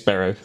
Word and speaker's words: Berrow: No, it's that everybody spells it wrong. Everybody pronounces it wrong. It's James Barrow.